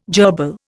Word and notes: jerbil: Indian

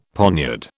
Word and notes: poniard: British English

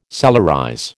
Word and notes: solarize: American English

